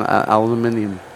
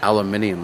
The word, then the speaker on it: aluminium: And aluminium. I love that word so much. I didn't realize how much we butchered our language until I heard aluminium.